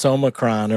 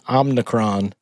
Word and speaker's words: Omicron: It's Omicron, or as many are mispronouncing it, Omicron.